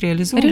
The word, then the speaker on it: реализовывать: Вот когда мы стали эту программу реализовывать, правильно говорю, ударение реализовать, реализовывать.